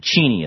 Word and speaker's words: Cheney: So anyhow, the bottom line here is that ever since Libby got nailed, that Cheney is the way Dick Cheney pronounces it. He likes the fact that everybody else mispronounces his own name.